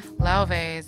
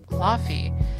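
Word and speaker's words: Lauv: A lot of people pronounce it Laffy. Guys, it's Lauve.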